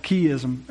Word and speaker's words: chiasm: chiasm. A chiasm. Did I say that right? Chiasm, sorry.